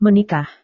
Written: menikah

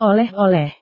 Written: oleh-oleh

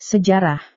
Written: sejarah

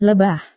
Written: lebah

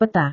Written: peta

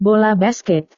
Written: bola basket

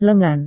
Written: lengan

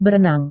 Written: berenang

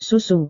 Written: susu

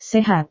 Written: sehat

